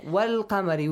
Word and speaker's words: والقمر: Look, wal-qamari. We pronounce that lam.